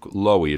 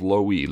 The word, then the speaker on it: Loewe: Lowy was a 3-year starter at William & Mary, and it is Luke Lowy. It's spelled L-O-E-W-E. It's two syllables, Lowy, Lowy.